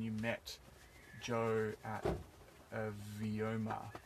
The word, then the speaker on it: Vyoma: I do believe it's pronounced Vyoma. Vyoma, which is a Sanskrit word for in the higher realms of the universe.